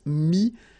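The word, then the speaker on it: mis: Mettre, E-T-T-R-E, will become E-S. Mi, same advice here, don't pronounce the final S like we had here. T is not pronounced, S is not pronounced.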